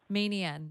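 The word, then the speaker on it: Meeniyan: Close, but I don't pronounce it properly either, because I'm actually from Melbourne originally. So I say Menian. – Meenian. – But I think it's Meenian. – Meenian. – Like, you sort of, you know, really relaxed bogan. Like, you just drop all of the syllables in between.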